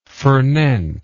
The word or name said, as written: fornent